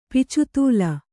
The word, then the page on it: ಪಿಚುತೂಲ: picutūla